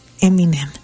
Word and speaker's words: Eminem: y se llama así, como se pronuncia, Eminem.